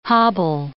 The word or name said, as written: hobble